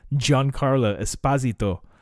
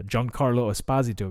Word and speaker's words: Giancarlo Esposito: But Gian- Carlo, which I learned in the like the last year or so how to properly say his name, Giancarlo Esposito because uh, he's uh, actually like Italian. Uh, Giancarlo Esposito, because I used to think it was Giancarlo.